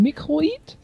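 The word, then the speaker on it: Microids: auf der Gamescom haben sie uns nämlich gesagt, dass sie sich französisch aussprechen. Microid? – Ach so.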